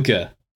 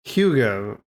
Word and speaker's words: hygge: It just, Hygge. I don't know how to say it. There you go. – There we go. – Hygge. – Hygge.